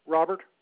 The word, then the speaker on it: Robert: Robert Ward. – Okay, your first name is spelled R-O-B-E-R-T.